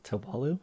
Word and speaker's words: Tewalu: So you recognize it as a religion dedicated to a god called Tewalu. – Could you spell that? – Yeah, T-E-W-A-L-U. – T-E-W-A-L-U.